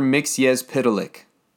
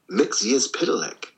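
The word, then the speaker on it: Mxyzptlk: Mr. Mixyez Pitalik. – Mixyez Pitalik. Mixyez Pitalik. Okay. – That's how it was phonetically spelt out in an issue. – They did. They did spell it out. Mixyez Pitalik.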